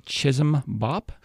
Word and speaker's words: Chisholm-Bopp: There's a mathematical method developed actually pretty recently within the last century called Chisholm-Bopp math that is done completely on the fingers. – I'm sorry. I'm going to make sure I'm saying it correctly. Chisholm-Bopp? – Chisholm-Bopp. Yeah, I believe he was from a Slavic country.